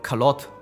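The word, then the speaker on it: carotte: 卡卡罗特其实是一个法语发音，叫 Carlot，应该是这么发音，就是发音不正确也没有关系。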